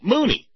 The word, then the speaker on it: Mooney: Oh, Mooney! Mooney! Rhymes with Looney.